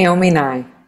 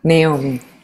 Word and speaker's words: Naomi Nye: name, Naomi Nye. Naomi.